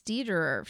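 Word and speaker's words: Dederer: Yeah, it's Dieterer. – It's not, yeah. It doesn't rhyme with Federer, yeah.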